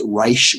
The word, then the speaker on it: Raich: And then that Reich case, but, and it's spelled R-A-I-C-H, but I think it's pronounced Reich and not Raich.